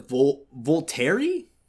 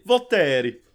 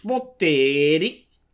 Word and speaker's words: Volterra: Basically, like, Edward is planning on going to Italy, to the Volterra? I don't know if I'm saying that right. And basically, he's going there to... – Oh, you didn't say it right. You have to say Volterra. – Volterra.